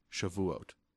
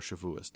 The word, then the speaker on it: Shavuot: The holiday I just finished celebrating was Shavuot, which is Shavuot. Or Shavuos, depending on your pronunciation.